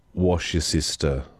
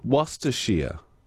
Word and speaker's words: Worcestershire: It's pronounced wash your sister sauce. Wash your sister sauce. No, it's not. Isn't it Worcestershire? It's Worcestershire sauce.